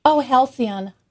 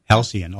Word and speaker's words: Halcyon: Halicon hosta? – Oh, I don't know. Halcyon. – Halcyon, okay.